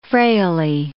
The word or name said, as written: frailly